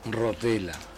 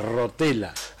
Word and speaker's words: Rotella: ¿Doble T o algo, no? – No. – ¿Rotella, sí? No. ¿Doble L? – No, tampoco. Así como suena nomás, Rotella.